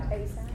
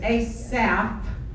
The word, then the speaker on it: Asaph: Asaph. – It's Asaph, not Asap, like we've got an Asap in the back. Could be. Because it is inspired, but it's Asaph, I guess, pronounced PH, who was a choir master.